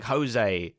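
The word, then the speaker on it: José: Spanish, because J's are pronounced H's, aren't they? – in Spanish. – Oh, yeah, it's like Jose.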